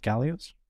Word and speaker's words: Galois: Galois. It's a French. He's a French mathematician and political activist from the 1800s. – It's Galois. Now and he died. – Now I'm 20 years that I mispronounced it for so long.